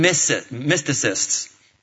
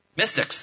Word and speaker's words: mystics: All we know is that they were called the Magi, and they were basically Eastern mysticists. Did I say that right? Mystics.